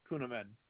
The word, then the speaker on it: Kuneman: K-U, N as in Nancy, E, M as in Mary, A-N, Kuneman.